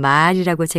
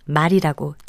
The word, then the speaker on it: 말: 지금 들으신 것처럼 말이라고 제가 길게 발음했는데요. 이것은 우리 입으로 나타낸 소리를 뜻하는 겁니다. 반면에 우리가 타고 다니는 동물의 하나인 말은 말이라고 짧게 발음하지요.